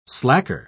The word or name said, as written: slacker